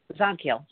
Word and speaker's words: Zadkiel: I don't know why I'm hearing Archangel Zagtail. Zadkiel, I would say Zadkiel. Okay. I have Zadkiel. I don't know if it's the same one. Z A D. – No, it is. Z-A-D. I'm just saying it wrong. – K-I-E-L.